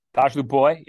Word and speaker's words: Tosh Lupoi: There's absolutely no reason to believe that the new defensive coordinator, and hopefully you can help me out with the pronunciation, Tosh Lupoi? – Tosh Lupoi, yeah.